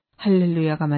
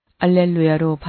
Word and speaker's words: 알렐루야: 또한 개신교를 다니시다가 개종하신 신자분들께서 알렐루야가 맞는지 또는 할렐루야가 맞는지 궁금해합니다. 개신교에서는 히브리어 그대로 할렐루야라고 사용하지만 초기 교회 당시 그리스어 발음과 라틴어 발음은 알렐루야로 발음하므로 가톨릭에서도 알렐루야를 사용하고 있습니다.